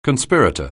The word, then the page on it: conspirator: anglais